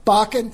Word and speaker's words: Bakken: There are also, if you read today's Ames newspaper, there's an article about pipelines that a Bakken company, I don't know if I'm pronouncing that right, B-A-K-K, Bakken, thank you.